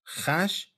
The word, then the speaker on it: خش: خش صورت کهن‌تر تلفظ واژه است که امروز خوش تلفظ میکنیم.